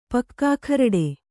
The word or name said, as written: ಪಕ್ಕಾಖರಡೆ